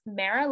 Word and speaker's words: Mara: After Dr. Kira, we have, I think she pronounces her name Mara because she says it's Mara like marinara.